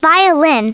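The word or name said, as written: violin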